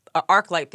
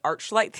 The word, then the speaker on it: ArcLight: And then we, like, had lunch together, and he mispronounced Arclight Theaters. He called it Archlight Theaters.